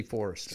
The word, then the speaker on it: Foerster: It's a Germanic name. And it was anglicized Foerster. – Okay. – Andy Foerster.